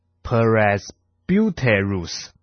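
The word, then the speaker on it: presbyteros (πρεσβύτερος): 而长老这个希腊文呢读作 presbyteros， presbyteros 这个字呢，它的意思是长者或是生命成熟的人。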